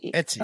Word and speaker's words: Etsy: Etsy.